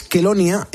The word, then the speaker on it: Chelonia: Os llamáis Kelonia, se escribe Chelonia, por si algún ponedor que nos está escuchando quiere investigar todo esto.